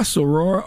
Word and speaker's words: soror: Is it Sarah, soror, or Sarah? – Soror. – Soror? – S-O-R-O-R.